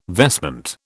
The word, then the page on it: vestment: British English